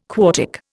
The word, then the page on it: quartic: Indian